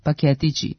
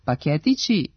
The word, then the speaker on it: paketići: U skladu sa tim bismo mogli da pretpostavimo da nominativ množine ove imenice može biti samo paketići, sa kratkouzlaznim akcentom na drugom slogu. Međutim, kao i većina deminutivnih oblika zličnih imenica tipa kaputići, kaputići, šeširići, šeširići, i imenica paketići ima regularan dubletni oblik sa dugouzlaznim akcentom na trećem slogu paketići.